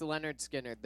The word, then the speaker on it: Lynyrd Skynyrd: Leonard Skinner, pronounced Leonard Skinner, their first album ever.